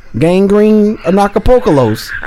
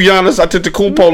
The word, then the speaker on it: Giannis Antetokounmpo: gangrene anacapocalos. – What? Who? Oh my God. Wait a minute. Who? Gangrene? – Acapocalos. – You know what? We're not going to do Giannis Antetokounmpo like that.